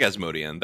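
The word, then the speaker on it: Asmodean: Or Asmodian, that's another one. I'm like, which way should I pronounce it? – I think Asmodian. That's how they say it in the audiobooks, at least.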